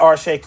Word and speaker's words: Arshay: Yes, at Arshe Cooper on Instagram and on Twitter. That's simple. – There it is. Arshe, A-R-S-H-A-Y.